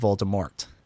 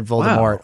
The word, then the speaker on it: Voldemort: Didn't Rowling post online after they did it that it's supposed to be a silent T? – Exactly. It's Voldemort, not Voldemort. But they said Voldemort all the way through the series.